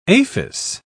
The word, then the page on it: aphis: British English